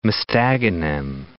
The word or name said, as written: Mostaganem